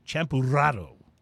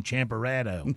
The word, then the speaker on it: Champurrado: Champurrado. – white boy pronunciation, Champerado. – Champerado.